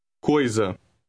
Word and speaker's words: coisa: Coisa. Coisa. – Note that the S in this word has a vowel before and after it. It is pronounced Z, as in the English word zeal.